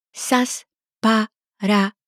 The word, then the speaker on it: Σας παρακαλώ: Σας παρακαλώ.